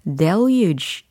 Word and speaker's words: deluge: 그 다음에 들렸던 목적어 부분에 deluge 라는 명사가 들렸어요. the deluge, the deluge, 그죠? d-e-l-u-g-e e 라는 철자입니다. 발음이 deluge가 아니고요. deluge, deluge. 이렇게 발음을 하는데요.